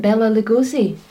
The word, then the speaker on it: Bela Lugosi: Oh, Bela Lugosi.